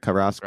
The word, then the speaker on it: Karras: Karras, right? – Yeah, screaming Karras.